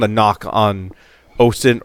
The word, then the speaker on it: OSINT: It's nice to have you here talking about OSINT. I said that correctly. – There you go. – Time. – OSINT.